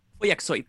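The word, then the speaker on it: Follakzoid: Creo que una banda, hay una banda también, Folaxoid podía ser, no sé si se dice así. – Foyaxoid, sí.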